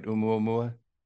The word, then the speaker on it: Oumuamua: Oumuamua? – Yeah.